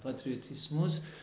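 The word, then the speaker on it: Patriotismus: آره اینو یکی از دوستان کمک کنه که چجوری تلفظ میشه؟ – پاتریوتیسموس.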